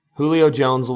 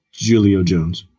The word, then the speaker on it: Julio Jones: Julio Jones will be fine. – Julio Jones. – Would you like, I know you don't like the J's. Both J's are pronounced differently.